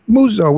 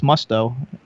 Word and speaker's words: Musto: Michael, well, it's Muzzo, we pronounce it. Michael, you know what his last name means in Italian? Face. Muzzo is a face. So his name is Michael Face. – But his last name's not Musso, it's Musto. – Well, that's how the Americans say it. But Musco is an Italian. – M-U-S-T-O.